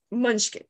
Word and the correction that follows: Mushnik: Munchnik. – Mushnik. – Okay, Mr. Yeah. Mushnik.